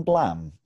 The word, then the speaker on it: Blame: Have you seen Blam? Spelt Blame, but pronounced Blam.